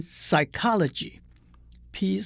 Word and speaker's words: psychology: 还有不发音的字母，例如心理学，P S Y C H O L G Y，连 psychology，P 是不发音的。